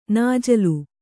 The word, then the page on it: ನಾಜಲು: Kannada